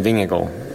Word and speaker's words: Vingegaard: Por cierto, ¿cómo hemos de pronunciar? ¿Jonas Vingegaard? ¿Vingegaard? ¿Cómo es? – Vingegaard. No, no es fácil.